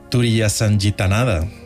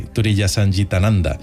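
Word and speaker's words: Turiyasangitananda: Alice sí que siguió en su finca espiritual como maestra hasta su muerte en 2007, se hacía llamar, a ver si lo digo bien, Swamini Turiyasanjitananda. – Ah, te ha salido muy bien. – Casi, Turiyasanjitananda.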